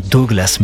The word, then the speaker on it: Douglas: Ça s'écrit Douglas, mais lui il dit ça comme ça, Douglas,